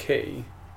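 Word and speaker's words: chi: And language, the letter that looks like an X, is which is pronounced key, which is the first letter of the Greek word for Christ.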